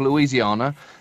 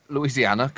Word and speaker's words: Louisiana: Now, Jindal is the governor of Louisiana. And Louisiana, God, you've got me doing it now. – Just say it properly. It's a long A. A's are long.